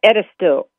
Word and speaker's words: Edisto: Did I say that right, Hope? – It's Edisto. – Edisto. It's not, right. Is it A or O? – Yeah, it's Edisto, E-D-I-S-T-O.